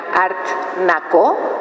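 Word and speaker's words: Art Nacó: Ustedes saben que Sergio es, su exposición se llamará Art Naco, con acento en la O, y así lo dice él.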